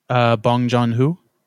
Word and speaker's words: Bong Joon-ho: Bong Joon-ho.